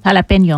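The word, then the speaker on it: jalapeño: California farmers destroying their lettuce crops, and now we got a jalapeno situation to worry about. Excuse me, pronounce it correctly for me. – Jalapeno.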